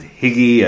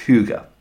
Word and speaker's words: Hygge: And I've heard lots of different pronunciations of this as Higgy and Hygge and Hygge and all these different unusual ones. But Hygge is, I believe, the correct way of pronouncing it.